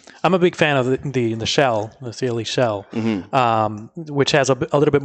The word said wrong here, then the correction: Chele: Chele, actually, is pronounced. – Chele? – Okay. Yes, yep, Chele.